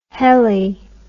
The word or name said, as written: heli